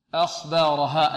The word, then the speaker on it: أخبارها: يومئذ تحدث ثو ثو ثو أخبارها، الهمزة مرققة والخاء مفخمة، لا نقول أخبارها آ لا آ آ أخ أخ بارها أخ بارها أخ بارها.